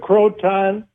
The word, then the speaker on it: Croton: Yeah, Wolves of Croton.